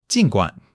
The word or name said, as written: jin3 guan3